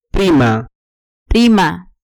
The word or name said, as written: prima